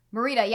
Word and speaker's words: Morita: Morita, yeah.